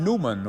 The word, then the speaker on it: Neumann: St. John Newman, or Neumann, you sometimes hear his name pronounced, prayed within these walls, as did Blessed Francis Xavier Silos.